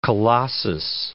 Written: colossus

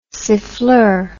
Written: siffleur

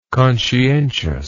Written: conscientiousness

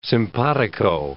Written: simpatico

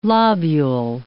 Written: lobule

